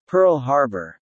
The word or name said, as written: Pearl Harbor